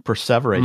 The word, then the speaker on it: perseverate: That is the word, perseverate. Mm. You even pronounced it right, I think.